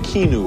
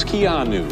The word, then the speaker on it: Keanu: I was saying Keanu or something. It's Keanu.